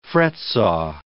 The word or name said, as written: fretsaw